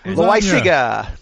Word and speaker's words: Loaisiga: Jonathan Loaisiga. I don't know if I said it right. – Loaisiga.